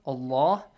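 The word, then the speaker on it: الله: Pronounce the word Allah with a heavy sound. Allah.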